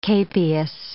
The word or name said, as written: capias